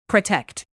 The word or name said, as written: protect